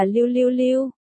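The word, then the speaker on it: lưu lưu lưu (六六六): Bởi vì đặc biệt lục lục lục, bản gốc yin wei te bi lưu lưu lưu, lục lục lục phát âm là lưu lưu lưu, gần giống niu niu niu, niu niu niu, là nghiêu trong nghiêu bước.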